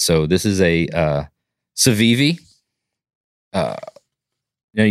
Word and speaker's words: Civivi: Civivi, yeah, C I V I V I.